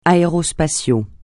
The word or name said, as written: aérospatial